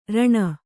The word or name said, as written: ಋಣ